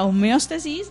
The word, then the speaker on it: homeostasis: La homeostasis, ¿sí, lo dije bien? Sí, correcto.